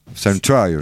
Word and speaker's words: Salem Trials: Il y a un problème sur ma prononciation. Ah oui, Salem Trials salem Trials. – Euh. C'est déjà mieux.